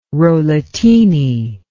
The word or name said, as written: rollatini